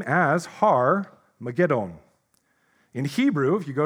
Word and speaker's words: Armageddon: But I'm actually mispronouncing it. It's actually Har-mageddon. Now, this has become popularly known, Armageddon, as the name for a battlefield in symbolic war.